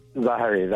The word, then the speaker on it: Zahariev: Zahariev, that's correct.